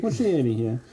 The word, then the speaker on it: Muschietti: It was directed by Andy Muschietti. I have no idea if Muschietti. – I pronounced that. Muschietti, yeah.